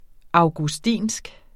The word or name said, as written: augustinsk